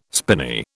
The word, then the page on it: spinney: British English